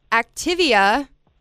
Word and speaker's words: Activia: Activia.